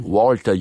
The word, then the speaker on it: Walter: I pronounce it the Austrian way, Walter, you would say, which is Mozart's favorite piano maker.